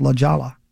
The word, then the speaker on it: La Jolla: Apparently, the best nude beach in the world is in La Jolla, California, which is actually, looks like it should be pronounced La Jolla.